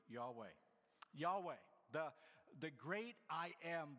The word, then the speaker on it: Yahweh: In Hebrew, what's referred to as the Tetragrammaton, YHWH, King James Version a few times translates that specifically as Jehovah. Most people think it's maybe better today pronounced as Yahweh.